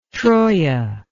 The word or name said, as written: troia